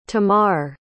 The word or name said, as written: tamarr